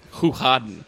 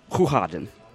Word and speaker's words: Hoegaarden: So how do you pronounce this beer? And it's apparently it's something I'm going to butcher it, but it's something like Hoegaarden. – Hoegaarden. Okay. – It's like you get a the G.